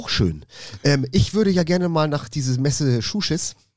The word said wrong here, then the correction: Massachusetts: Kannst du das aussprechen? – Massachusetts heißt das. – Massachusetts.